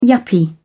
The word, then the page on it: yuppie: British English